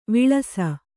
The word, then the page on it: ವಿಳಾಸಿ: kannada